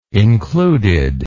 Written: included

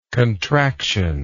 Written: contractionary

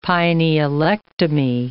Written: pinealectomy

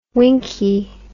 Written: winkie

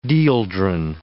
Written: dieldrin

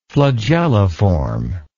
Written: flagelliform

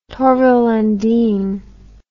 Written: torvill and dean

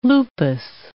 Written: lupus

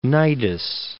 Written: Cnidus

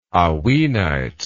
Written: hauynite

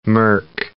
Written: merc

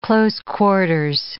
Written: close quarters

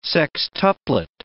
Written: sextuplet

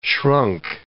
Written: shrunk

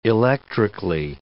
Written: electrically

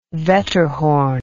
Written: Wetterhorn

